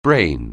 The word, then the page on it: brain: anglais